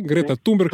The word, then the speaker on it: Greta Thunberg: Yo menciono a Greta Thunberg, que pronuncio bien, ¿sí? Greta Thunberg. – Claro, claro.